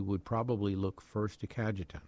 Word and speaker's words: Cajetan: Today, the church celebrates the feast of Saint, and you may have to help me out on pronunciation, Cajetan? Cajetan. – Cajetan.